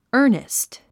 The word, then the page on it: earnest: ə́ːrnist; アァニスト